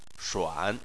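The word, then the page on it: shuan3: tone 3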